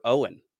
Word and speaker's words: Eoghan: didn't know that E-O-G-H-A-N was Irish for Owen.